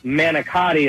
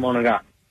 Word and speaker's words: manicotti: It's manicotti is how everybody else pronounce it. But if you grew up in an Italian family or Italian household, you know it's called Monogat.